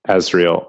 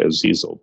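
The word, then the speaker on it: Asriel: Azriel. – For some reason I always want to say Azizel, but that's a different character and a different thing.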